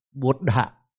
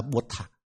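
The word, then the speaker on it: Buddha: Ví dụ nói ta có chữ Phật Pháp, đọc chính xác là Đức Phật là Bụt Đạ, họ đọc là Bụt Thạ.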